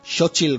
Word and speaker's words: Xochitl: La revelación de esta película con su personaje América Chávez, la actriz Xochitl Gómez, que es además, creo que se pronuncia así, si no me equivoco. – Sí, lo has dicho bien.